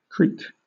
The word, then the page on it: creak: British English